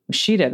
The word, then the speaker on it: Ouachita: Ouachita. – There you Ouachita. – Go. Ouachita.